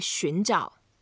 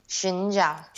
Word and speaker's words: 寻找: So the way to say scout in terms of searching, we say 寻找. – 寻找. – 寻找. And 寻 is to go around. 找 is looking for something to find out exactly what you are looking for. So 寻找 is the way we say scouting.